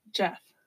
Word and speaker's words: Geoff: Geoff. – Jeff. – His name was Geoff. – Why do they have the weirdest spelling name? Like, literally, that's Jeff. – G-E-O-F-F. – Yeah. Some people pronounce that Jeff.